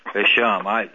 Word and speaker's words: Hisham: You said it right the first time. – Hisham. Because most languages in the world emphasize the ah rather than the a.